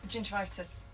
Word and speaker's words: gingivitis: So gingivitis, correct way to say it? – Yes, yeah, perfect.